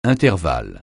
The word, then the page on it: intervalle: francais